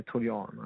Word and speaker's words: Toljan: Och Jeremy, jag kanske inte uttalar rätt, Toljan från Hoffenheim. Jeremy Toljan, ja.